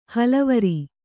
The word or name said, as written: ಹಳವಾರ